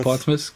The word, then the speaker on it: Portsmouth: Portsmouth. – Portsmouth, genau.